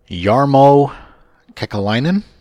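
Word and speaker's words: Jarmo Kekäläinen: The next one on the list is Columbus Blue Jackets GM Jarmo Kekalainen.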